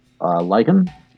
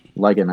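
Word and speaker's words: Licon: I mean, I wish it was spelled like lichen, like werewolf, but no, it's like it's L-I-C-O-N. It's like, oh, you were so close.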